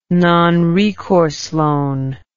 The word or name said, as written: nonrecourse loan